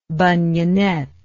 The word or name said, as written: bunionette